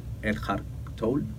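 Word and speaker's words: Eckhart Tolle: Te traje incluso cómo se escribe el, autor que es Erhard Toll. Ok. Eh, así se pronuncia.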